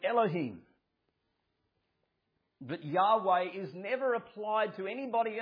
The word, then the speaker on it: Yahweh: This Hebrew word, often referred to in the older translations, Jehovah, and in but more modern scholarship pronounce it as Yahweh.